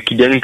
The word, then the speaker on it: Kylian: Benoît, c'est B-E-N-O-I-T-E et Kylian, K-Y-L-I-A-N.